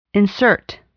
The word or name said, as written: insert